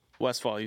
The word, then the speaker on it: Westphal: Westphal, you said it right, yeah.